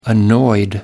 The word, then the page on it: annoyed: American English